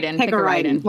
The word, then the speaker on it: picaridin: Next in my arsenal against ticks, thanks to Andrea, I have DEET and I have Picardin? How do you say it? – Picaridin. – Wow, I was close.